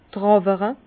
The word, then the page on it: d' Tràwere: Bas-Rhin; Herrlisheim